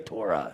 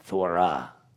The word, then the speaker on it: Torah: So how would you actually say this properly? So it's Thorah. Kind of like Thor. Ooh, you're not going to get in trouble if you say that word. It's Thora. And it's not really, it's not, it's like, I believe all the let.